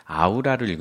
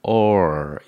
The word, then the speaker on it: aura: 근데 영어를 읽을 때는 아우라를 읽으면 독일어의 발음에 가까운 거고요. 영어에서는 or 이렇게 읽어야 되는 거죠.